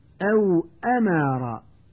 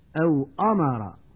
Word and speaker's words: أو أمر: والآن مع الدرس الخامس والعشرين ومع القراءة الصوتية الثانية والتي سأحاول من خلالها تنبيه المتعلم إلى الأخطاء النطقية الشائعة في الكلمات التالية: أو أمر، ويقول من يخطئ أو أمر بتفخيم الهمزة والميم، لا تطعه، ويقول من يخطئ لا تطعه بإدغام العين في الهاء.